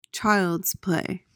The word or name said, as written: child's play